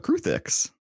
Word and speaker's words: Kruthik: Anyways, today we're talking about Kruthiks.